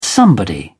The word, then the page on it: somebody: British English